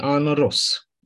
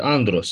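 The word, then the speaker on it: andros (ἀνδρός): Dar este andros, s-a băgat un D acolo. Un infix, să zicem așa. O literă, de ce? Nu pronunțau aia bine, anros. Și au băgat D, andros. Deci nu are ce căuta D, nu face parte din radical. A apărut de dragul pronunții ușoare.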